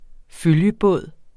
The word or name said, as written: følgebåd